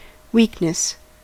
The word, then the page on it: weakness: American English